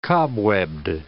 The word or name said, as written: cobwebbed